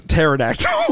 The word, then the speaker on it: pterodactyl: I read it as pterodactyl. Pterodactyl. – There you go. – I realized just reading it out loud right there that I was like, should have pronounced that tear. Shouldn't have pronounced the P.